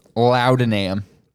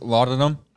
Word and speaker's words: laudanum: It's probably pronounced laudanum, but... It's whatever. So the spelling is L-A-U-D-A-N-U-M. Laudanum. – I was kind of hoping it'd be loud and ham. – Laudanum. So that's how we'll say it.